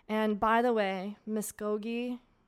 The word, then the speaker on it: Muscogee: It's definitely Muscogee, like almost pronounced K-O-H.